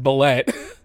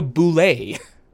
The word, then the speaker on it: bulette: And I'm really sorry to say this, but it's not pronounced bulette. It's not pronounced bulette. I was prepping according to the guy who made it. – What's it called? – It's called a boulet.